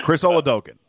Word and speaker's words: Chris Oladokun: Yeah, Chris Oladokun. That's how you say it.